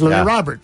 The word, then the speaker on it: Robert: You know, the T's are tricky because remember when White Sox, we first heard about Louis Robert? – Yeah. – It was Louis Robert. – That's what people were saying. – And then we found out that, well, that's not true. It's you pronounce the T's, Louis Robert.